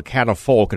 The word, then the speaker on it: catafalque: And help me pronounce that word, the catafalque. – It's called a catafalque, and it took me about 3 years to learn how to say it.